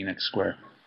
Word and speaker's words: Enoch Square: So they'll play at the top and then walk halfway down, play again, and then the main Piping Live center is at I'm going to say this wrong. Enoch Square. – Yeah, Enoch Square.